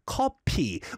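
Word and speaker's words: 커피: And in case anyone was wondering why the answer is coffee, is because if you pronounce coffee in Korean way, it's pronounced as kopi, which is similar to kopi, nosebleed.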